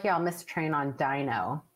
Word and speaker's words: Deno: Which is that Dino is the canonical pronunciation, but when it was originally announced by Ryan Dahl, who's the original author of Node.js and then created Dino, in the first talk that he introduced it, he said Deno, and then later decided that it would be better, like sort of pronounced as Dino. So it sort of switched.